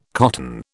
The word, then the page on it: cotton: British English